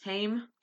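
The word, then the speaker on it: haem: Hame. Hame. Yeah, that's how it's pronounced. You can you can say hemi for he or he hemi for hemoglobin or hame. It's just different British enunciations, American enunciations. Yeah.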